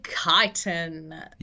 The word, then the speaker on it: chiton: It is the papatua snakeskin chiton. – Yeah, so, that's what I said. That H is silent, everyone. Don't make that mistake.